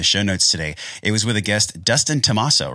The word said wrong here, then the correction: DiTommaso: right? Is that how you pronounce his last name? – Yeah. – Dustin Tommaso? – Dustin DiTommaso. And Dustin DiTommaso.